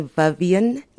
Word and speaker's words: Vivienne: If you also maybe want to go on Facebook, you can find me Vivian Nisa Prungu. You can spell it as V-I-V-I-E-N-N-E. And my surname Prungu is P-R-U-N-G-U.